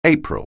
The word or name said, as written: April